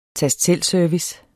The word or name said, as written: tast